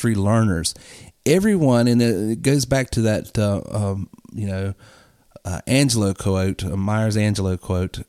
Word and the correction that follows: Maya Angelou: and I'm mispronouncing her name, I know, and I can't think of it, but she basically said, Maya Angelou,